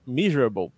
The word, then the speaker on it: measurable: Depois a gente tem aqui o mi, miser, é quase miserável aqui a pronúncia, measurable,